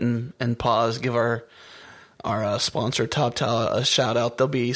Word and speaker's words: TopTal: I mean, I got to admit, if no one has heard of TopTal, and it's spelled TOPTAL, TopTal, like as in top talent,